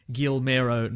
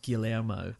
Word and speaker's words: Guillermo: Now, before we get to spoilers, somebody just tweeted, listening to Old Weekly Planet Pod, and noticed that Mr. Sunday Movies always pronounces Del Toro's name Gilmero, not Guillermo.